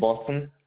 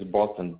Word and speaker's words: Boston: Hey, my name is Boston. Last name is Moyeyo. – You say your name is Dustin? – First name is Boston. B-O-S-T-O-N.